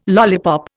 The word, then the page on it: lollipop: Indian